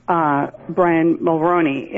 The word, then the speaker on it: Brian Mulroney: It was signed on to by your Prime Minister, Brian Mulroney, and I guess I'm saying his name right, I don't know. – Yes, you are.